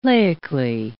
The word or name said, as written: laically